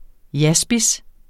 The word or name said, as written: jaspis